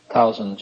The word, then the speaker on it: Tausen: Tausen, just for the sake of record, is spelled T-A-U-S-E-N, one S, I think. Tausen.